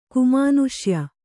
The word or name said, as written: ಕುಮಾನುಷ್ಯ